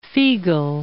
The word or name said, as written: Figl